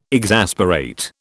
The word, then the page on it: exasperate: British English